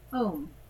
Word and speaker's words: Aum: The pronunciation of Aum moves through all possible human linguistic vowel sounds, and is different from the pronunciation of Om. Both are often symbolically equated, although they are sonically distinct.